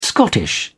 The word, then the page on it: Scottish: British English